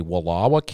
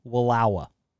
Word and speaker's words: Wallowa: Oh, you got to say it right. I'm not going to let you say it like that in here. Wallowa. – What did I say? – Wallowa. – Okay. Wallowa. – There you go. – Wallowa. – Get that twang in there.